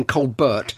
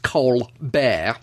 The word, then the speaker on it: Colbert: I don't know if you heard of either of these men, but Alton Brown, the food scientist, and Stephen Colbert, or it's written Colbert, he pronounces it Colbert.